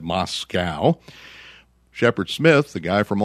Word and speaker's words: Moscow: pronounced Moscow correctly, something that Rachel Maddow and Lawrence O'Donnell do not do when they're on MSNBC. They say Moscow, and there is no cow in Moscow.